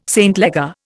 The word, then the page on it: St Leger: Indian